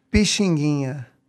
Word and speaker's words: Pixinguinha: Pixinguinha. – Not a chance. – Pixinguinha is the master composer we have.